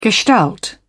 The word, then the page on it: gestalt: British English